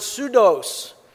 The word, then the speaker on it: pseudos: The word falsehood here in the original language is the word pseudos, with a silent P at the beginning.